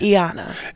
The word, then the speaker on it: Y'anna: then A-N-N-A. So it's right. Y'anna. – It's pronounced like a long E.